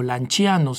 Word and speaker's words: Lanciano: que había sido testigo del milagro eucarístico del Lanciano, o Lanciano, si lo pronunciamos en su original en italiano,